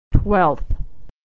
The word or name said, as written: twelfth